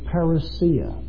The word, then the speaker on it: parousia: It is parousia. If you're taking notes, that word in its transliterated form is spelled P-A-R-O-U-S-I-A. Parousia.